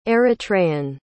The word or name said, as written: Eritrean